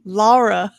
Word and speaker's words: Lara: So Lara.